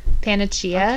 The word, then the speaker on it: panacea: Yeah, help me out with this one. Panacea? Panacea? What is it? – Panacea. – Okay.